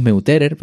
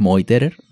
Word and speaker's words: Meuterer: Vamos, decimos Meuterer, pero en alemán es Moiterer, ¿no? La pronunciación.